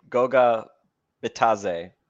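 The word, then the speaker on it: Goga Bitadze: Kane, Goga Bataze. There's the D is silent according to the Pacers game notes.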